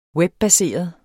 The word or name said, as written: webbaseret